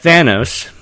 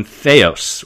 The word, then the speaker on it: Thanos: repeatedly has his name mispronounced by the heroes of the film. Brown says they constantly drop the N, calling the villain Theos, or Theos, again, from the Greek, literally calling him God.